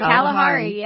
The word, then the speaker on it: Kalahari: Is that how you say it? – Kalahari, yeah.